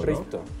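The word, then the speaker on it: Hristo: ¿Cómo se pronuncia realmente Risto, ¿no? – Risto. – Risto. O sea, la H es muda, literal.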